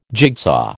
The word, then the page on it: jigsaw: American English